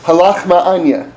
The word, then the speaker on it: ha lachma anya: it's ha lachma anya. That, that's how you say it.